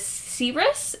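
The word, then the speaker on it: Ceres: Next, we have the goddess Demeter, who became the goddess Cirrus. Is that how you pronounce it? – Yep. – Okay, it's spelled like Cyrus, like Miley Cyrus, and yeah, that threw me off.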